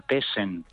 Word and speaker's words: Tshen: Sí, hay un jarabe natural, el nombre es un poco raro de pronunciar, se llama Tessen. – Tessen. – T-S-H-E-N, Tessen, T-S-H-E-N,